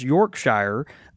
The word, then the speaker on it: Yorkshire: However, if one calls Yorkshire his or her home, it is pronounced Yorkshire. And he says that Yorkshire. So that's the pronunciation style, Yorkshire.